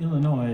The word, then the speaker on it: Illinois: Illinois, is pronounced usually Illinois, except in bluegrass when you add S's at will, add and subtract at will.